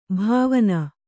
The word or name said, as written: ಭಾವನೆ